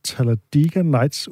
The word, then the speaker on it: Talladega Nights: Rasmus, taget to klip med, som øh, begge to er fra den film, der hedder Talladega Nights, udtaler jeg det rigtigt? – Ja, Med Talladega undertitlen Nights.